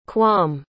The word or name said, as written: quam